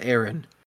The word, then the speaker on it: Ewen: Yeah, the person that creates this, Aaron Clooney, spelled E-W-E-N, but pronounced Aaron.